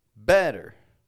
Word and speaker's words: better: The common way for Americans to pronounce the word better has it stronger on the beginning.